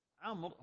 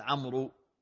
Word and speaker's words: عمرو: عمرو هنا يقال مما إيش؟ تكتب ولا تنطق الواو الزائدة، ما يقال عمرو، إنما عمرو.